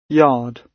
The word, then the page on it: yard: British English